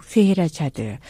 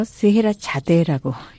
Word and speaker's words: 스헤라자드: 이 대표작 중에 하나가 바로 모음곡 스헤라자드. 예전에는 한국에서 스헤라자데라고 읽었었는데요. 너무나 유명한 고전입니다.